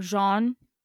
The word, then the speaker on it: Jean: So the number one suspect in Reet's murder is John, or Jean, how it would be pronounced in Montreal.